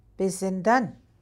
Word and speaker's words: Bizindan: So say it like you're, these are commands, so you gotta kind of. – Oh, yeah. Okay. – You have to voice them like they're commands. Bizindan.